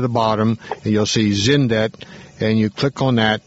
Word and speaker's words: Xyndet: There's a product line, XYNDET. XYNDET, pronounced Zindet.